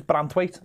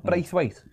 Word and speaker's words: Branthwaite: You know, his name's Branthwaite, not Braithwaite.